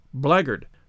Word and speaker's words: blackguard: But the word blackguard isn't pronounced as it is spelt. It's pronounced blackguard.